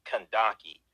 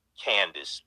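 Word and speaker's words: Kandake: Okay, now the term uh, Kandaki, uh, which is also pronounced Candace, um, but or mispronounced Candace, but the term Kandaki that, that means Queen Mother or Queen Regent.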